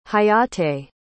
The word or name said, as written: hayate